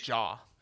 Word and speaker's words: Jah: Is it Jay Joyner? J-A-H? – Jaw. – Jaw? Okay. Jaw Joyner.